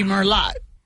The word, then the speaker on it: Merlot: It would be Merlot. It says Merlot. – I guess so, yeah. – Yeah, remember Merlot is, and that T is silent the T on is the silent.